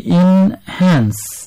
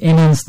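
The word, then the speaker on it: enhance: এর উচ্চারণ হবে ইনহ্যান্স ইনহ্যান্স এনহ্যান্স না ইনহ্যান্স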